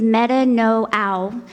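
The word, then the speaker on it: metanoao (μετανοέω): The actual Greek word used most commonly in the Bible, in the New Testament, to talk about repentance is metanoao. It's not spelled like that, but that's how it's pronounced.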